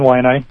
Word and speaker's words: Waianae: Yeah, but we are in Waianae? – We're in Waianae.